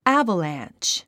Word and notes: avalanche: ǽvəlæ`ntʃ; アァバラァンチ

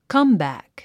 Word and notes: comeback: kʌ́mbæ̀k; カンバァック

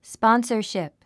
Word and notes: sponsorship: spɑ́nsə r ʃìp; スポォンサシップ

